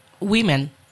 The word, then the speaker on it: women: Okay, and that's the correct pronunciation as well. Woman, women. Okay, but some people say woman's by mistake, because normally we tend to add an S for the plurals, but not in this case.